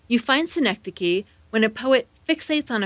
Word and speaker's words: synecdoche: Synecdoche is a specific type of metaphor in which you use part of something to describe all of it.